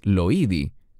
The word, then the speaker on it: Loida: Nosotros pronunciaríamos este nombre como Loida, pero en griego es Loidi.